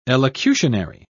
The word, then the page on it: elocutionary: British English